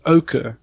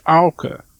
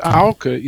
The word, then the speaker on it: Auke: So my name, for example, in South Africa, you pronounce it Oka, almost like the color. But in Frisian, it's Auke. And Auke is an English sound, like cow.